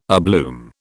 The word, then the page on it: abloom: British English